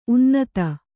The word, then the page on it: ಉನ್ನತ: unnata